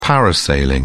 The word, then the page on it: parasailing: British English